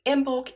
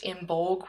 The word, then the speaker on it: Imbolc: Uh, the celebration can also be pronounced Imbolg with a hard G, and you may know it uh, to yourself as the Feast of St. Bridget rather than Imbolc.